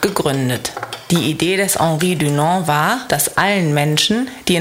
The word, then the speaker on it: Henri Dunant: Das bedeutet, dass die Straße nach Henri Dunant benannt wurde. Das wird französisch ausgesprochen, aber der Mann kommt eigentlich aus der Schweiz.